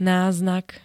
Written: náznak

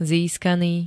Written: získaný

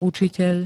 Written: ucitel

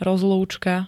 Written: rozlúčka

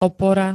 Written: opora

